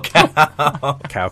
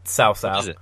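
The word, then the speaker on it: Cao Cao: Oh, Sheik's Cow Cow. – Cow Cow. – It's not how it's pronounced, Corey. Sao Sao. – Is it really? – Yeah, Dynasty Warriors 3 did such a bad job with the pronunciations.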